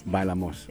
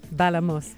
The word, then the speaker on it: Bailamos: Bailamos. Yeah, Bailamos.